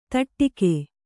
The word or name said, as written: ತಡಾಗ